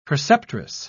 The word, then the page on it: preceptress: British English